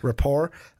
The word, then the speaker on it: rapport: Instead of rapport. – Silent T. – Rapport.